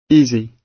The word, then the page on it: easy: British English